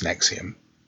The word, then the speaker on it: NXIVM: So it's not obvious when you first see it how to pronounce it, but it's known as Nexium.